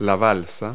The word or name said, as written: raft